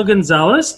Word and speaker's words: Gonzalez: So uh, today's special guest is Gabrielle Gonzalez.